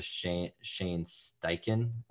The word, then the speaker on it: Shane Steichen: His name was Shane Steichen. I might be pronouncing that, but mispronouncing that's correct.